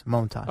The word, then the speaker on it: Montas: You're going Montas? – Moan. Yeah. Baseball Toss. Reference. Montas. – Okay. – Yeah. – Frankie Montas. Baseball Reference has pronunciation.